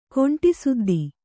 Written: ಕುಂಟುಸುದ್ದಿ

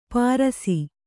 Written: ಪಾರಸಿ

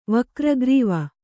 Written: ವಕ್ರಗ್ರೀವ

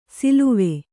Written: ಸಳವ

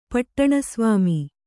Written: ಪಟ್ಟಣಸ್ವಾಮಿ

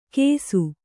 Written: ಕೇಸು

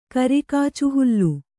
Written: ಕರಿಕಾಚುಹುಲ್ಲು

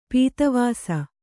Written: ಪದಾವಾಸ

